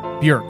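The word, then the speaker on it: Björk: Bjork, whose name rhymes with work, not pork. Bjork. – Bjork.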